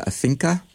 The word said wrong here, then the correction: Afinque: is that how you pronounce them? – So Orquesta Afinque.